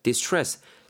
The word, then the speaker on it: distress: distress. 생긴 거는 dis 뭐 이렇게 이렇게 가는데 발음은 dis stress 이렇게 비슷하게 느끼잖아요.